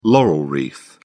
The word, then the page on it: laurel wreath: British English